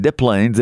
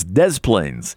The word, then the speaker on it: Des Plaines: That's the way you pronounce it. It's not Des Plaines. It's Des Plaines, Illinois, 1955.